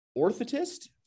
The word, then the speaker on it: orthotist: You're a vice president of the NWBA for many years and currently working as a prosthetist, orthotist.